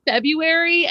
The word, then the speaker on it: February: It's February, and I've always said it that way, and it's cracked me up when people pronounce the R. It's a silent R. This is common knowledge in the English language. There are so many silent R's.